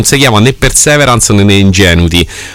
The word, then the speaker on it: Ingenuity: Ingenuity eh, che si chiama così, questa è la sua pronuncia, è inutile che dite cose diverse,